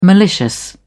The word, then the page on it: malicious: British English